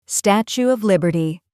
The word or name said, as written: Statue of Liberty